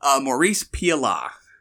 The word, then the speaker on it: Maurice Pialat: Maurice Pialat.